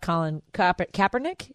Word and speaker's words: Colin Kaepernick: I also loved the conversation you had about Colin Kaepernick.